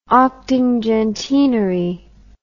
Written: octingentenary

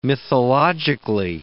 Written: mythologically

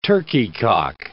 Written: turkey-cock

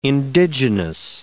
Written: indigenous

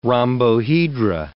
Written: rhombohedra

